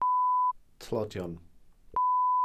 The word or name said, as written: tlodion